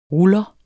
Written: ruller

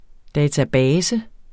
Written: database

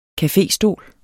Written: caféstol

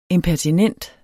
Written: impertinent

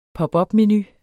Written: menu

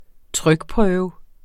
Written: trykprøve

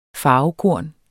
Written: farvekorn

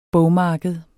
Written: bogmarked

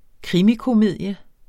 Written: krimikomedie

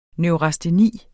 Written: neurasteni

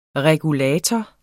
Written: regulator

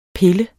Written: pille

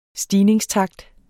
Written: stigningstakt

